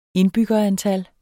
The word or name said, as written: indbyggerantal